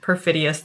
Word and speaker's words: perfidious: Your father went towards her to hit her, but the doctor pulled him back and said to keep in mind her condition, perfidious though it was. Did I mispronounce perfidious? – No, I think that's right.